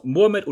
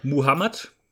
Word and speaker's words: Muhammad: Genau, Mohammed oder Muhammad kann in beiden Formen ausgesprochen werden.